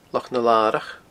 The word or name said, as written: Loch na Larach